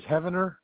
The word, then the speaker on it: Heavener: Yeah, my first name's Ken, K-E-N. Last name is Heavener, H-E-A-V-E-N-E-R. – Hold on, spell that again, H-E-N. – H-E-A, V as in Victor, E-N-E-R.